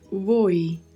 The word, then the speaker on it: voy: É importante ressaltarmos aqui a pronúncia voi, voi. O, a letra V no espanhol ela tem um som muito leve de B de bola.